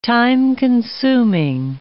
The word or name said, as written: time-consuming